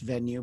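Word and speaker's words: VNUE: Reading up on you, I saw at first that you're the CEO of a company that I assume is pronounced Venue, but it's V-N-U-E. So I figured I'm just filling in the E, and it's not some crazy like Venue. You know, it's not some alien word.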